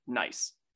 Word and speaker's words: gneiss: We would call these types of rocks like gneiss. And it sounds like we're saying nice, but it's spelled completely different.